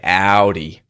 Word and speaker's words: Audi: You don't even need to say the L. It's a dark L. You just say Audi.